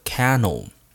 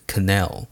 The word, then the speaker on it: canal: Suez Canal，好，Canal 这个单词呢，不要念错，不是 Canal。那这个单词的念法呃拼法呢是 C A N A L，C A N A L，Canal。再一次哦，不念成 Canal，是 Canal。